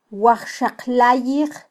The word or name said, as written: wajxaqlayij